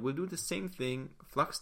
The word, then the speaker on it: f.lux: The app is called Flux, F.Lux. So F.LUX. So that's how you spell it.